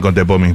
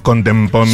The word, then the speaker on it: Contepomi: Me encanta que pronuncias bien Contepomi, porque muchos decimos Contempomi.